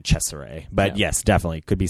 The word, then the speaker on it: Cesare: Well, when I look it up, because it's spelled C-E-S-A-R-E. And then later in the film, once it's revealed that the doctor's inspired by this Caligari from Italy, I have to assume that it's Cesare.